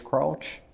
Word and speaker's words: Crouch: Jan, I don't know if I'm pronouncing this, C-R-O-U-C-H, Crouch? – Crouch.